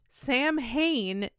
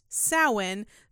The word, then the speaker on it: Samhain: And I'm going to stop right here and say, yes, I know it is most commonly pronounced Samhain when referencing this band, but that's wrong. – That's incorrect. – It is incorrect. It's pronounced Samhain, so that's how I'm going to say it.